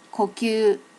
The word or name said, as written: kokyu